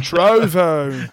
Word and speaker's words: Trovo: You can find me at Sod Passion Gaming on YouTube, Twitch, and Trevor, or however you pronounce the site's name. – Trovo. – Trovo, okay. – It's like Trove, but with an O at the end instead of an E. Trove, Trovo.